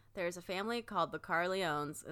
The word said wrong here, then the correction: Corleone: Is that how you say it? Maybe. – Corleone. – Corleone.